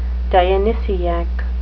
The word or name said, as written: Dionysiac